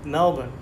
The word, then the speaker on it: Melbourne: And some reports say you are from, and I'm going to say this wrong because I'm pretty sure Americans always say it wrong. It's not Melbourne, is it? – Melbourne. – Yeah. All right. Well, I mean, you can say Melbourne. – Okay. Yeah. Americans, I think, always say that wrong.